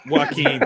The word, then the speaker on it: Joaquin: I say Joaquin, right? My wife makes fun of me. I don't pronounce words right. – It's Joaquin.